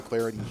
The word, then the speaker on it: Clardy: Troy Clarity here. You can follow me on Twitter at Troy Clarity. Last name is spelled C-L-A-R-D-Y.